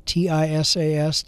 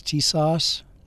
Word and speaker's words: TISAS: It's TISAS, TISAS, is how I've heard it pronounced.